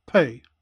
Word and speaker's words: Peh: Note I did say with the exception of the last syllable, which you can see is spelt P-E-H and is pronounced pay.